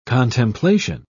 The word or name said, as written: contemplation